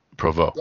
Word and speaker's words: Provost: I don't know how you pronounce Provost in French. – Provost.